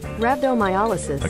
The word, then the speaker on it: rhabdomyolysis: There is something to that, because what she ended up with was called rhabdomyolysis. – Rhabdomyolysis. Can you say it right? – Rhabdomyolysis. – Okay, thank you.